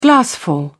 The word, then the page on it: glassful: British English